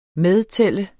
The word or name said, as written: medtælle